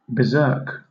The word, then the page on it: berserk: British English